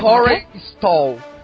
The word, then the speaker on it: Corey Stoll: Corey Stoll.